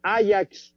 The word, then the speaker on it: Ajax: No, no, este es que, se pronuncia Ajax.